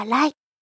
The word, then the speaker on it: lie: LIE。読み方はライ。